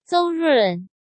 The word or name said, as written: Zou Run